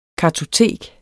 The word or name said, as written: kartotek